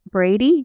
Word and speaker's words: Brady: It is Lisa, LISA, B as in Brady, at Brady, B-R-A-D-Y, insurance, well, INF.group.